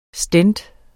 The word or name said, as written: stent